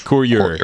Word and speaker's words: Courier: Et, courrier c'est courrier, bah demande, ce que demande dans la chatroom si courrier se prononce aussi courrier en anglais. En fait, courrier. Voilà, c'est Courier. Courier.